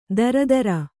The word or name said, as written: ದರಿದ್ರೆ